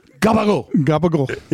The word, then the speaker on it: gabagool: My wife would pronounce Capicola like that. – Capicola. – I go, stop. – Capicola. – What did you just say? Capicola. That's what it says. That's how it's spelled. I go, don't you ever say that either. It's... Gabagool. – Yeah. – She's, how do you get gabagool out of Capicola? I go, it's there.